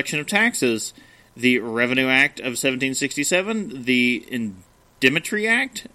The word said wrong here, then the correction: indemnity: Indemnity. – Oh, I'm adding extra syllables.